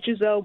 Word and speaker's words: Giselle: I say it Giselle because I prefer the English pronunciation.